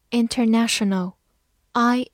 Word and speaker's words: international: international，i n t e r n a t i o n a l，international，i n 发音，t e r ter，n a n t i o n 省。 a l 和前面的 n 连读，no，international， international，它是一个形容词，表示国际的。